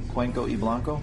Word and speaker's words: Cuenca y Blanco: Okay, we're trying out a brand new cigar, Cuenca Y Blanco. Did I say it right? – Cuenca and Blanco. – And Blanco. – The Y is and. Okay. – Isn't it pronounced E? Cuenca y Blanco? – Cuenca y Blanco.